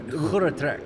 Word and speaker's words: Groot Trek: How do you say that? – The Groot Trek. There we go. You've got to just clear that phlegm out.